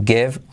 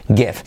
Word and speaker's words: give: To give up. Give. Se pronuncia con I ah, como E y una V, semiclave. Give, give, give up.